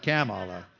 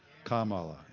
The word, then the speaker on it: Kamala: Did I say it right? Kamala? Not Kamala. Kamala.